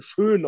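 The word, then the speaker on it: Schoen: Ähm, der, der General Manager der, der Giants, ja, Joe Schön, Schön auf Deutsch ausgesprochen, ja, war der Assistant, Assistant GM von Brandon Bean in Buffalo die letzten fünf Jahre.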